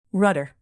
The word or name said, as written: rudder